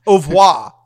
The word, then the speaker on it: au revoir: how do you say bye in French? Bon revoir? – Bon voyage? – Bon voyage? Au revoir. Au revoir.